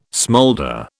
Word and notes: smoulder: British English

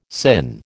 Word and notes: sinh: American English

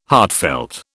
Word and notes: heartfelt: British English

